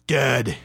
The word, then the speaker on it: DED: For any new metal fans out there, Dead. D-E-D. Dead. That's how you spell it.